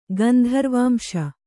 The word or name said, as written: ಗಂಧರ್ವಾಂಶ